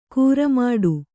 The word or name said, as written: ಕರ್ಮಠ